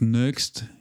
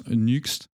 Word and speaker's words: Nuxt: Donc Nuxt, certains prononcent Nuxt, il me semble avoir entendu euh, Sébastien Chopin euh, dire euh, Nux. Voilà. nous, après, on a l'habitude de dire Nux, donc après, bon, on, c'est d'usage, on va dire.